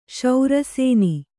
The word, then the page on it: ಶರಸೋನೆ: śara sōne